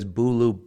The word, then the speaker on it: Bulu: So that is Bulu, B-U-L-U. Does that name mean anything? – Okay. So B-U-L-U. Yep. We say it like Hulu, but with a B.